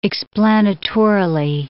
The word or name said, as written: explanatorily